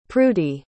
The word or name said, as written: prudie